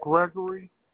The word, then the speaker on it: Gregory: Gregory. Gregory.